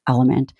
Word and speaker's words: LMNT: It's spelled L-M-N-T, and it's pronounced Element.